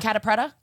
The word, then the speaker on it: Catta-Preta: Catta-Preta? – Yeah.